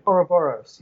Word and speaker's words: Ouroboros: Ouroboros, yes.